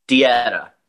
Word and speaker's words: Detta: Is it Detta Almon? How do we pronounce her name? – Dieta.